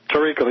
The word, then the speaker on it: Torrico: You say Torrico, right? – Torrico, yes. – And I just put the emphasis on the wrong syllable, and I've been practicing.